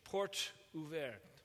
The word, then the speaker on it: Porte Ouverte: People tagged their message, Porte Ouverte. Did they say it right? I did. Okay, it's close enough.